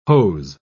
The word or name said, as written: hose